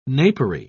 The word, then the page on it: napery: British English